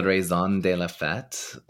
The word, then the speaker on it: raison de la fête: I'm gonna say this wrong. The Ré de la Fête. the, raison, the raison de la fête.